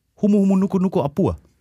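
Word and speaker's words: Humu Humu Nuku Nuku Apur (Humuhumunukunukuāpuaʻa): Humu Humu Nuku Nuku Apur. – Du mich ja auch. War das richtig betont? Das war richtig betont und war auch richtig ausgesprochen und es ist kein Scherz.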